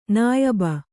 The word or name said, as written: ನಾಯಬ